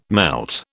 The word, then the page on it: melt: British English